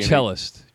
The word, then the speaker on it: cellist: I don't know if that's how you pronounce cellist, Steven. – Oh. And you know, I guess I since they offered me the part, I guess I can pronounce it anyway. Now how do you say it? what do you say? I don't want to be cellist. Cellist. – Cellist. Yeah. So you don't pronounce the O.